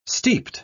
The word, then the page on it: steeped: British English